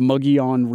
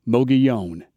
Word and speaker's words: Mogollon: His last name is spelled the same as the rim, and it was probably pronounced Mogollon. Over the last 300 years, the pronunciation has been corrupted into Mogollon.